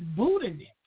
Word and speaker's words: Boudinet: Now, I want you to contact David Boltnet, and I'm hoping I said it right, or Boltnet, and it is spelled B-O-U-D. I'm sorry, it's Boltnet, B-O-U-D-I-N-E-T.